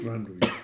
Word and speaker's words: Thranduil: Er ist der Sohn von, wie spricht man das aus, Thranduil? – Thranduil.